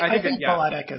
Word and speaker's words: balletic: It's like ballet with an IC. Balletic. – Balletic is right.